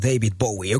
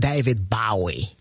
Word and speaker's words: David Bowie: Vamos a comenzar con un poquito de música y este clasicazo del señor David Bowie. O como algunos de mis amigos lo pronuncian, David Bowie.